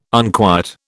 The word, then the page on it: unquiet: British English